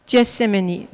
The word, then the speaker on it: Gethsemane: Ensuite, ils vont dans le jardin de, comment on prononce, Gethsemane? Gethsemane, merci.